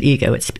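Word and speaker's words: Igoe: I pronounce it Igo. It's spelled I-G-O, that last bit.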